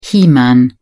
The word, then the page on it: he-man: British English